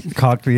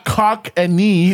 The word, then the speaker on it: Cockney: And like, definitely don't look up Cockney. – Cockney, yeah. Something else is going to come. – Not cock and knee. No, no.